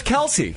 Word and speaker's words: Kelce: No, it's Kelce. – Okay. – It's just a little fun fact. – Right. – Their name is actually pronounced Kelce.